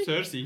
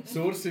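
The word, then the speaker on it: Sursee: Sursi. – Hallo. Sursi. – Sursi, Sursi. Ja, Sursi ist richtig gesagt.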